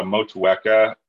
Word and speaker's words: Motueka: I brew with a Motueka, which I believe is New Zealand as well. – Pretty good pronunciation there as well, we've got to say.